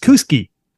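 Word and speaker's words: Kooskia: It's K-O-O-S-K-I-A. It's pronounced Kuski. – But the A is silent.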